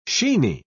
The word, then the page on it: sheeny: British English